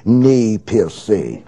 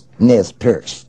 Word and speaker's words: Nez Perce: Final figure I would like to turn to is Chief Joseph of the Nez Perce, or if you want to be pompous, mispronounce it in a good French manner, speak of the Nez Perce, but it is pronounced Nez Perce.